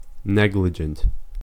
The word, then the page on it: negligent: American English